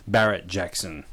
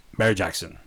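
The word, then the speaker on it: Barrett-Jackson: Now, this is pronounced Barrett Jackson, not Barry Jackson. For years, I've thought it was Bear Jackson, because Americans do not pronounce words very well. – It's definitely Barrett Jackson. – Barrett Jackson. That's how you say it, guys.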